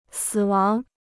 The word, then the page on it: 死亡: sǐ wáng